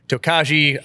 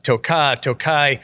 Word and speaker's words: Tokaj: So, and first of all, I want to talk about the pronunciation. Tokaj, the pronunciation is something that's kind of debated with the industry. You hear a lot of people say Tokaji, Tokaj. I did a lot of research and I decided to follow suit with how they pronounce it, where it's made in Hungary. And the videos that I could find on it, they all pronounced it Tokaj. And so I wanted to follow suit from them.